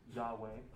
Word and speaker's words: Yahweh: YHVH or Jehovah or sometimes Yahweh is how it's pronounced.